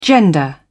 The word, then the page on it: gender: British English